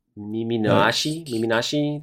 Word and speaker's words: Miminashi: Because, like, his name is, I'm just looking at him, Miminashi, the earless is how it's pronounced according to the way it's pronunciation chart.